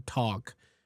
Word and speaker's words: talk: But I would say that I've never heard anyone saying the L. So I would say that that's a pretty standard, pretty international way of saying the word walk or talk.